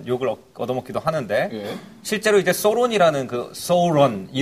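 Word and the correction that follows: Sauron: 이런 식의 그 발음이 대체로 사우론이라고 음. 예, 네, 사우론.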